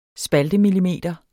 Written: spaltemillimeter

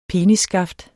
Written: penisskaft

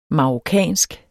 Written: marokkansk

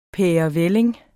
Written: pærevælling

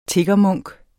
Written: tiggermunk

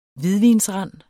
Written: hvidvinsrand

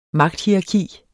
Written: magthierarki

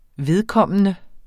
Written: vedkommende

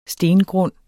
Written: stengrund

